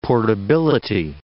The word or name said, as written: portability